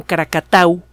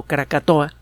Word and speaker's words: Krakatau: En 1883, en la noche del 26 al 27 de agosto, están cumpliendo eh, años de, esta erupción famosa, el volcán Krakatau, mal pronunciado Krakatoa,